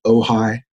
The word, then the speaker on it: Ojai: Gavin, am I pronouncing that right, Ojai? – Yeah, you got it exactly right.